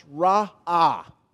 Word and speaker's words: ra'ah: The Hebrew word there is a word pronounced ra'ah. R-A apostrophe A-H is the way it's transliterated into English.